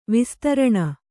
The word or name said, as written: ವಿಸ್ತಾರಣ